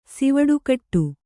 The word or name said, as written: ಸಿವುಡುಕಟ್ಟು